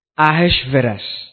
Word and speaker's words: Ahasuerus: In the first year of Darius, the son of, now let me help you pronounce this, Ahasuerus, of the seed of the Medes, which was made king.